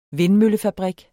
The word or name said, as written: vindmøllefabrik